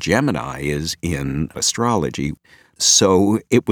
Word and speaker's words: Gemini: So for this, I decided to respect NASA tradition, and throughout, I use the pronunciation Gemini.